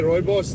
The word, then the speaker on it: rooibos: If you say rooibos, then you're good to go. That's how it's pronounced.